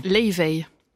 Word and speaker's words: Laufey: Leivey. – Comment ? – Elle est islandaise, Leivey. – Ça se dit comme ça ? – Ça se dit Leivey ? – Leivey. – Ça s'écrit L-A-U-F-E-Y, Leivey.